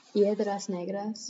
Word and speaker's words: Piedras Negras: Piedras Negras, Yeah.